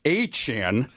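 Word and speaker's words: Achane: I'm going to give both Mostert and Achan, as we've learned, is the pronunciation.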